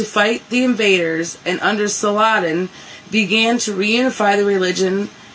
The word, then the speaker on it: Saladin: Also true, although generally I think we redneck it as Saladin. Saladin. But he's Saladin. Rhymes with Bin Laden, see? – Though the Crusaders failed...